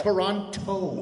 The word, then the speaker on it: Toronto: Toronto. Well, I'm getting, see, uh, in Canada, the second T is silent, it's Toronto.